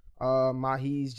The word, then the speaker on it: Mahees: M-A-H-E-E-S Brandy on Instagram. Mahi's, just like it's spelled, it's pronounced how it's spelled.